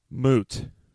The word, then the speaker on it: moot: Moot. – Moot. – Yeah, you know, it's a moot point, not a mute point.